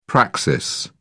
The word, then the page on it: praxis: British English